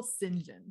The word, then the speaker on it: St. John: Why do we say it like that if it's not spelled like that? It makes me upset. – It's so stupid. – See how it was spelled. – It's spelled like St. John.